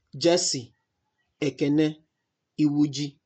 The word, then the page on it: Jesse Ekene Iwuji: iˈwuːdʒi; ee-WOO-jee